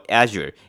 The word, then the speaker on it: Azure: 那这个呢，这个业务呢，A Z U R E 呢，它的读音呢，其实就跟这个 A 九很像，要念快一点的是 A 九 A 九。那其实它的音就是正确的发音呢，应该叫做 Azure Azure 这样子。